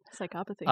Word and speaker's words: psychopathy: Psychopathy. – Psychopathy.